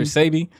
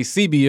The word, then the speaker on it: Sebi: Come to find out, I learned that our ancestors said I-A-I-O-U instead of A-E-I-O-U, like Dr. Sebi. Some people say CB if they're not familiar with how to pronounce his name, right? The E makes an A sound.